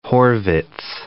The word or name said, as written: Horvitz